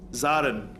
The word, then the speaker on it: Zarin: Was it Darin? The first question? Darren. Dazin? Because I don't like to get people's name wrong. People pronounce my name wrong all the time. – Zarin, thank you.